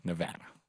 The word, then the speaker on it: Nevada: Yeah, Nevada.